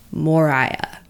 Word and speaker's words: Moriah: Well, I think it's kind of like supposed to be pronounced like Jeremiah or Hezekiah, kind of ma- masculine actually, like Moriah.